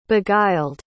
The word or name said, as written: beguiled